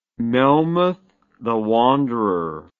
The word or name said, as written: melmoth the wanderer